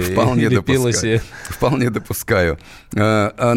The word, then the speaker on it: Пелоси: которая правильно произносит не Пелоси, как многие другие говорят, а Пелоси. У нее итальянская,